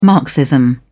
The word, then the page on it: marxism: British English